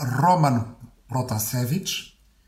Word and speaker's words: Roman Protasevich: É dizer Roman Protasevich, e isso quer dizer que estás a pronunciar à maneira russa,